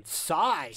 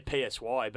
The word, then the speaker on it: Psy: That was Gangnam Style by the great man Psy. – I used to say PSY, but apparently it's Psy. Apparently it's Psy. That's how you say it.